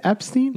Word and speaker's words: Epstein: Is it Epstein? – Yeah. – It's Epstein, right? – Yeah. – Not Epstein or some shit.